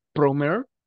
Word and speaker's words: Promare: Este, se llama Promare, eh, se escribe así como se escucha pro, pero en el mare es M-A-R-E, Promare,